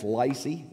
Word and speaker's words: Laicy: That is Lacey. Yep, and I'm saying that right. That's not my country drawl. L-A-I-C-Y. Lacey Terry, and he is your Awana missionary to the eastern part of Cuba.